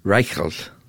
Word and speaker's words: Reichelt: And I would love to hear you say his name properly. – Franz Reichelt. – That's pretty good.